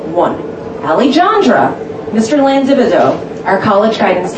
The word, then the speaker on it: Alejandra: Three, Alejandra, Miss Sanders, junior year physics. Technically this is correct. The third syllable is pronounced like a Han in Hanukkah.